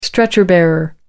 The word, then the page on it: stretcher bearer: American English